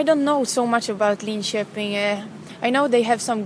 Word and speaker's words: Linköping: Is that how you say it? – We say Linköping.